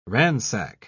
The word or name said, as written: ransack